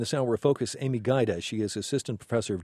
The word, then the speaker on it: Gajda: If you're going to look for the book, her last name is spelled G-A-J-D-A, pronounced Gaida.